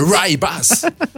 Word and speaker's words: Rooibos: Ah, moi, je le dis comme je le dis à la ville franchoise. – Le Rye Boss. – Alors, en tous les cas, je vais l'épeler. Ça s'écrit Rooibos. Voilà, donc on peut le prononcer comme on le souhaite.